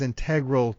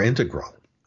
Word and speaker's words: integral: Or integral is the way I say it. – Or integral. Yes, that's right. That's the other pronunciation. So we have two of them, but it doesn't always get pronounced integral or integral. – Now, the R gets pushed back into the previous syllable, so it comes out as integral.